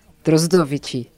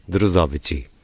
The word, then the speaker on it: Drozdovychi: E, portanto, o nome da aldeia é Drozdovici. – Drozdovici. – Exatamente.